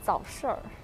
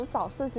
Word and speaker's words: 早市儿: 然后，哦哦，我先说早市，你要读成早市儿，就是，反正就是，嗯，不然读早市其实怪怪的，就好像只有读早市儿的时候，就去逛早市儿，然后才有那种在东北逛那个街道的那种感觉。